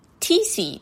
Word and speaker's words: Qixi: それは QIXI と書いて TC って読むんですけれども、まあちょっと私のこの発音、どのくらい正しいかちょっとね、あの、よくわからないんですけれども、あの、これ中国語なのでね、 TC というえー、